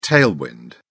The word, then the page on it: tailwind: British English